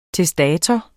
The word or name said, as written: TESTATOR